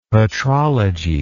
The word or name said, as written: patrology